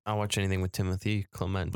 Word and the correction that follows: Chalamet: But Timothy Chalamet is amazing. – Is that how you say it? – Yeah, Timothy Chalamet.